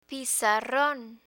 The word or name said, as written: pizarron